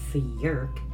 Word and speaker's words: fyerk: It is pronounced fjerk, and it is spelled fjerk. No, actually it's spelled F-Y-E-R-K.